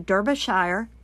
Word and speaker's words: Derbyshire: Now, it's all part of a highly successful forensic search dog project pioneered by the Derbyshire Constabulary. I looked it up. That's how you say it.